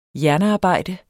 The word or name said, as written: hjernearbejde